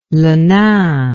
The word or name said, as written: le nain